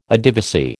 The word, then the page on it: Adivasi: American English